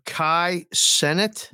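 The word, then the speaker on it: Kai Cenat: Kid or Kai Sennett. – Yes. That's it. I don't know how to say it. – C-E-N-A-T.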